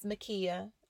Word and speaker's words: Milkia: See, guys, my name is Makia, and my mom spelled it M-I-L-K-I-A.